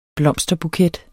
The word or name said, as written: blomsterbuket